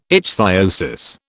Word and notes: ichthyosis: British English

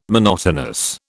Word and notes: monotonous: British English

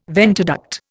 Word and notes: ventiduct: Indian

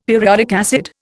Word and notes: periodic acid: Indian

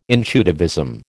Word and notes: intuitivism: American English

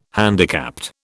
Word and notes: handicapped: British English

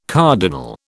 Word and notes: cardinal: British English

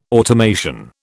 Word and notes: automation: British English

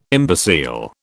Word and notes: imbecile: British English

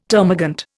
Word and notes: termagant: Indian

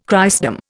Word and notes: Christendom: Indian

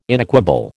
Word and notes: inequable: American English

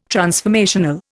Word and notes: transformational: Indian

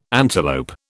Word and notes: antelope: British English

